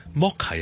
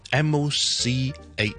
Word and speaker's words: mocha: 咁 Mocha 有啲人咧 MOCHA，有啲人咧因为见到 CH 咧，佢就会将佢读成咧 cha 就变咗 mocha 咁样。事实上咧就净系一个 K 音嘅啫，mocha 就更加唔好将佢同呢个抹茶捞乱。